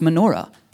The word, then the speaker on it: menorah: the word lampstand means, it's pronounced menorah in Hebrew.